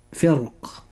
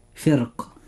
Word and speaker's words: فرق: وكلمة فرق، كلمة فرق يجوز ترقيقها وتفخيمها لأنها ساكنة وقبلها كسرة وبعدها حرف استعلاء مكسور، وتفخم فقط عند الوقوف عليها. – أي الإخوة المؤمنون، وهذه الكلمة هي الكلمة الوحيدة التي وردت في القرآن الكريم، فحينما ننطقها مفخمة يكون نطقها هكذا فرق، أما إذا كانت مرققة فيكون نطقها هكذا فرق.